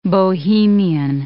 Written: Bohemian

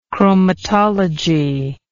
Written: chromatology

